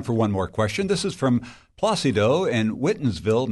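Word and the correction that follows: Whitinsville: Massachusetts. – Whitensville. – Whitensville. I apologize. – That's how they pronounce it.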